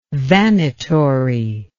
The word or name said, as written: vanitory